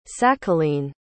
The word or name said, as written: sakhalin